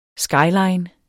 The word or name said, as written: skyline